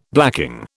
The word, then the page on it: blacking: British English